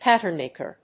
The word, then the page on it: patternmaker: páttern・màker